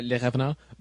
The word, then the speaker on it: Les Revenants: Uh, would you like to give the Montreal pronunciation? – Les, Les Revenants.